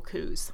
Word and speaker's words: coups: Welcome to It's a Clue, a faux crime comedy podcast for students of political coups. Did like I say that right? – You did. A political, a coup d'etat, perhaps? – I know it's not a coup, but I also don't, you don't frequently see political coups in the plural.